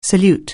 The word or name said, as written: salute